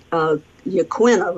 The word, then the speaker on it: Yaquina: This week we've been on the Yaquina River a lot, Yaquina River, I think is how they pronounce it, actually.